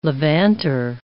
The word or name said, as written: levanter